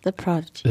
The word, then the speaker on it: The Prodigy: Weil ich so oft das Wort Podigy ausgesprochen habe und das so oft, also dass ich mich wirklich gefragt habe, heißt die Band The Podigy oder The Prodigy? – Ja, The Prodigy.